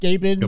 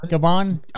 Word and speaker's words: Gabon: Jackson visited Africa in early of, of 92 on his first stop in uh, G- Gabon. – Gabon. – Gabon. – I think Gabon, yeah, I think that's how it's pronounced.